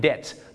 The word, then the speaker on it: debt: debt ต้องออกเสียงว่า debt I'm in debt ไม่ใช่ debt นะฮะบ้านเราชอบออกเสียง debt ว่า debt ไม่ได้นะครับ